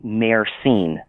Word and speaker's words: myrcene: Cannabis, marijuana, low THC, high CBD marijuana may also have something called myrcene. – Okay. Can you spell it? – M-Y-R. – Yeah, thanks. – Yes. M-Y-R-C-E-N-E.